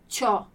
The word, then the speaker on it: c'ho: E la pronuncia qua, com'è questa pronuncia di ci più il verbo avere? Se è con la prima persona, con io, dirò c'ho.